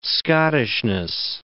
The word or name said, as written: Scottishness